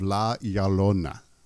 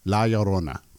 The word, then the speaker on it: La Llorona: And what she wanted to do was the urban legend of La Llorona. And I probably butchered that. I've been trying all week to say it correctly, and then I fucked it up. La Llorona. There you go.